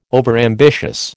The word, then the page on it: overambitious: American English